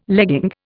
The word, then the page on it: legging: Indian